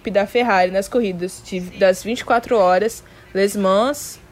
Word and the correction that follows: Le Mans: Le Mans, na verdade, né? Se é francês, vou ter que pronunciar certo, na França.